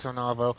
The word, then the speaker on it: Onavo: Is it Onavo? – I yeah, think O-N-A- yeah, I believe it's Onavo. Uh,